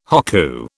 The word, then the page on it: hokku: British English